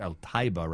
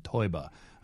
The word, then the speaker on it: Taiba: It's al-Taiba rather than Toiba.